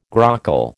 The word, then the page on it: grockle: American English